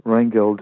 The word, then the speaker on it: Reingold: And we're going to be working with Jonas Reingold, so Jonas, to pronounce it in Swedish, Reingold, who's a legend amongst the bass-playing fraternity.